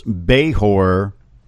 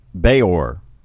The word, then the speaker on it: Bajor: Jeanette says, please pronounce Behor like you say the name George, Orge, Behor, Behor. That's really as close as I can get, and I apologize if I am not pronouncing it right, but B-A-J-O-R, Behor.